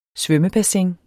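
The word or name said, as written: SVØMMEBASSINS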